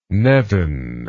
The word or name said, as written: nevin